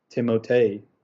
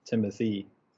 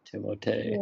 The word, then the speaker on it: Timothée: Yeah, also, it's actually yeah. pronounced Timotei, not Timothy. Timotei.